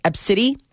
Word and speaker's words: ABCDE: And when they got up to the gate and handed the boarding passes, the employee began laughing at her 5-year-old daughter's name, which is spelled ABCDE. It's pronounced Ebsidi, and it is just, I mean, really? – I would imagine.